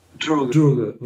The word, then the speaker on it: Droege: Droege, okay.